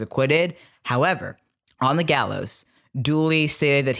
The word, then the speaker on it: Dula: But Dula insisted, I'm saying Dula, it's pronounced Dooley, sorry, because the Appalachian way that you right. – pronounce the A at the end is E.